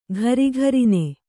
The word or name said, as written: ಘರಿಘರಿನೆ